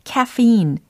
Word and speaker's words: caffeine: 근데 어, 예전에도 알려드린 것처럼 카페인이라는 것을 영어적으로 발음을 한다면 caffeine 이렇게 연습을 해본 적이 기억이 나실 겁니다.